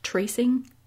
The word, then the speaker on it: TRCNG: They have Sonamu, they have what I assume is pronounced Tracing, T-R-C-N-G, and they also had Secret.